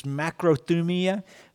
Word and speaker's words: macrothumia: I do know how to pronounce this word. It's macrothumia. Macro for a long time, thumos, heat. You stay under it for a long time, and it embraces steadfastness, staying power.